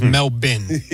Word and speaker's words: Melbourne: Well, I thought it was Melbourne when we started today, but it's Melbourne, okay. And that is courtesy of Noah Eagle. – Yes. Yeah, so Melbourne. The Australian pronunciation, is Melbourne.